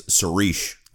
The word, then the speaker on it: Saoirse: I think I'm saying that right. My wife told me I always pronounce it wrong, but I think it's Saoirse.